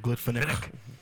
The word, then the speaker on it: Glenfiddich: Glenfiddich. At the end of Glenfiddich.